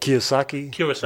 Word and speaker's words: Kiyosaki: Robert Kiyoshi? Was it Kiyosaki? – Robert Kiyosaki.